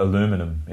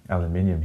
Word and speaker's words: aluminium: Well, aluminum in the US, aluminium here.